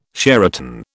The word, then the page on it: Sheraton: British English